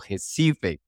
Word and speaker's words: Recife: Esta vez la doctora Adenilza Paiva desde Recife o Recife, como se pronuncia en Brasil.